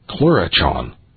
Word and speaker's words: Clurichaun: It's spelled C-L-U-R-I-C-H-A-U-N. Clurichon.